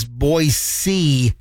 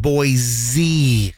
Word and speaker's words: Boise: It's Boise, not Boise.